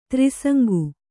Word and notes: ತ್ರಿಸಂಗು: trisaŋgu

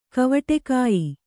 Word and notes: ಕವಟೆಕಾಯಿ: kannada

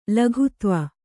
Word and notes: ಲಘುತ್ವ: laghutva

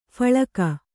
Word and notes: ಫಲ್ಗು: kannada

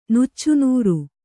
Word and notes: ನುಚ್ಚುನೂರು: kannada

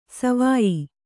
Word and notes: ಸಿವಾಯಿ: sivāyi

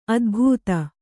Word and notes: ಅದೆಬದೆ: adebade

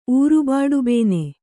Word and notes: ಊರುಬಾಡುಬೇನೆ: ūrubāḍubēne

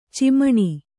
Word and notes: ಚೆಮ್ಮಣಿ: cemmaṇi

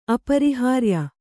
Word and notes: ಅಪರಿಹಾರ್ಯ: aparihārya

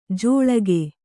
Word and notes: ಜೋಳಗೆ: Kannada

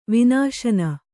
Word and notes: ವಿನಶನ: vinaśana

